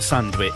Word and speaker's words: sandwich: And it's like, but it's spelled S-A-N-D-W-I-C-H. And I said we always pronounce it sandwich, S-A-M-W-I-C-H, sandwich.